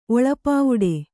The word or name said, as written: ಒಳಪಾವುಡೆ